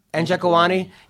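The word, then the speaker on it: Njokuani: How do you say his last name? Njikwani. Yes. Cool.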